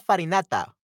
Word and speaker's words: farinata: Se pronuncia farinata. What? Faina, farinata. What? What is the nata? Farinata, ok. Anyway, farinata, aunque para el genovese es faina.